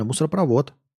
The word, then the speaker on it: мусоропровод: Мне кажется, мусоропровод правильно. Ошибка допущена в слове мусоропровод. Ударение падает на последний слог. Правильно, мусоропровод.